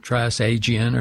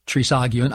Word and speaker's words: trisagion: The Greek word for it is trisagion, the trisagion hymn, and that's how it would be pronounced, not trisagion or something, but trisagion.